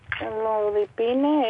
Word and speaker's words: Amlodipina: ¿Y el amlopidina? – Amlodipina es de... Amlodipina. Siempre lo digo mal.